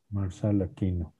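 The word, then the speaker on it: Marçal Aquino: Sí, se dice así, Marcial, ¿verdad? La cedilla. – Marcial Aquino.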